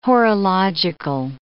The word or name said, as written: horological